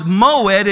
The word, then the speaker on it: moed: The Hebrew word for feast is pronounced moed in Hebrew, meaning appointed times.